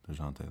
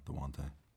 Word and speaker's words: Dejounte: DeJounte. There you go. DeJounte. Silent J.